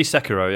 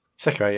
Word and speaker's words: Sekiro: Probably, would you pronounce it Sekiro or Sekiro? – It'd be Sekiro, yeah. – Sekiro, yeah. Okay, yeah, I thought I was doing it wrong.